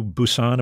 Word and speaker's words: Busan: Your family moved to Busan.